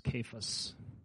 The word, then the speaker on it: Cephas: Okay, so we know him as Peter mostly, but Cephas, and actually, if we pronounce that right, it's Cephas.